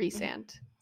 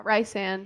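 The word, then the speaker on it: Rhysand: Resand. It's not Rysand.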